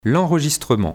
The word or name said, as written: l'enregistrement